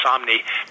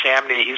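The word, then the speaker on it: Samnee: You know, again, I like to do stuff that I have an affinity for, and Chris Somni, it's actually pronounced Somni, because every time I talk to him and I say Chris Samney, he always corrects me, and rightfully so.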